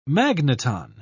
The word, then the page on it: magneton: British English